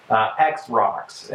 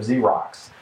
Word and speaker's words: Xerox: And so, like, at a very young age, I was saying things like X-Rox instead of Xerox.